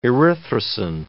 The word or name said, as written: erythrosine